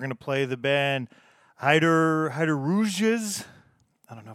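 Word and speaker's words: Heideroosjes: And here's a phonetic pronunciation. Heiderouges. Heiderouges. So there we go.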